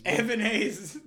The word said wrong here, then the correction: Elvin Hayes: Elvin Hayes.